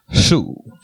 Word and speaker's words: Hsu: Sue, what is the HSU? How would you pronounce it? – Sue. – Sue? Tiffany Sue.